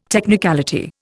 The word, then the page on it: technicality: Indian